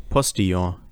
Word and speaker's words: Postillon: Also die ähm, auf Instagram Real Postillon, die, die so, spricht man das überhaupt so aus? Postillon, ja. Okay.